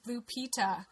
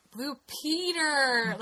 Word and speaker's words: Blue Peter: And when I first heard them refer to it, I thought they were saying Blue Pita. Because they don't pronounce their goddamn R's.